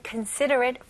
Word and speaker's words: considerate: Okay, let's take a look at considerate first. Considerate, 일단 간단하게 사려 깊은 이라는 뜻을 가지고 있고요. 발음 연습해 볼게요. 자, 여기서 음절 하나씩 하나씩 considerate. 마지막에 여기 ate이지만 그냥 at 이런 식으로 발음하시면 돼요.